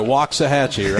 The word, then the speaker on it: Waxahachie: I nailed the pronunciation right. Waxahachie, right? – You did.